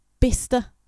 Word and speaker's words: Bicester: But then like Bister. Bicester. Honestly, we spell words so stupid.